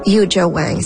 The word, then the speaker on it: Yuja Wang: Et puis, Yuja Wang, c'est comme ça qu'on prononce son nom.